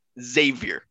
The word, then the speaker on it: Xavier: And he's like, it's pronounced Xavier, like xylophone.